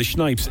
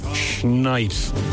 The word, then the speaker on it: Shnipes: So there you go, the Schnipes. S-H-N-I-P-E-S. You have to say it like you're taking a bite out of it. Schnipes.